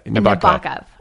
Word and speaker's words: Nabokov: Nabokov. – Nabokov, yeah. – Nabokov. – Yeah, I know. – Is that how you pronounce it? – Nabokov. – Yeah.